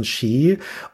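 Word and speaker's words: Sidhe: Shi spricht man das aus. – Shi spricht man das aus? – Ja, das ist ja ein Originalname aus der keltischen Mythologie.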